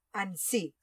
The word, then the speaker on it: Annecy: que en verdad se pronuncia Annecy. Si no me equivoco, se pronuncia Annecy, pero yo le llamo Annecy, así que me iréis escuchando más de una vez decir Annecy. Lo primero que hicimos fue ir al lago de Annecy a buscar un camping.